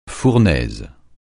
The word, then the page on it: fournaise: francais